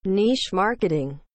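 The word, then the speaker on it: niche marketing: تلفظ درستش در اصل اینه: نیچ مارکتینگ.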